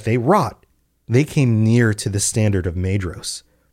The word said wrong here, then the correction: Maedhros: And I just realized I've been doing it again. Pronunciation is rough. Maedhros—it's It's a DH. I talked about this on the last episode, and yet I forgot. Maedhros, by the standard of Maedhros.